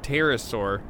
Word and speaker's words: pterosaur: A Tumblr post made the rounds claiming it was a leathery-skinned pterosaur, with a silent P, and in fact, this description better coincides with the Tombstone Dragon.